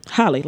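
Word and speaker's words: Halle: Did I say it right? Holly. – Holly, like Holly Berry.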